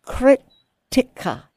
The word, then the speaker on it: Krttika: And that's a crit. Tikka. Is that how you say it? – Yeah. – K-R-T-T-I-K-A.